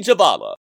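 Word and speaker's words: Jhabvala: Washington football team insider Nikki Javala of the Washington Post.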